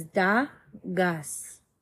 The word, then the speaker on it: Dagaz: Pronunciation is Da Gus.